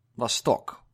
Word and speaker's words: Vostok: Vostok 1, in proper pronunciation.